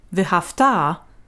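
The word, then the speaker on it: ve'ahavta: V'hafta is perfect, and you loved. V'hafta, stressed on the final syllable, is imperfect.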